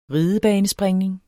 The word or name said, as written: RIDEBANESPRINGNING